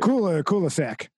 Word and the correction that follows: Kulusuk: Kulusuk. – Yeah. Well, it's a regional, how you pronounce it.